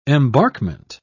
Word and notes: embarkment: British English

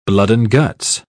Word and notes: blood-and-guts: British English

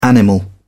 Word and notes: animal: British English

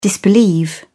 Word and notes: disbelieve: British English

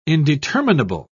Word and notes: indeterminable: British English

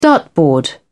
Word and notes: dartboard: British English

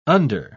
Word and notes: under-: British English

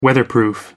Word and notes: weatherproof: American English

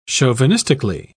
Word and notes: chauvinistically: British English

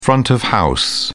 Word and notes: front of house: British English

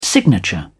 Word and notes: signature: British English

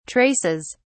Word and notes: traces: British English

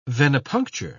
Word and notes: venipuncture: British English

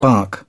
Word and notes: bark: British English

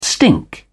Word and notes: stink: British English

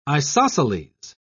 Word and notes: isosceles: British English

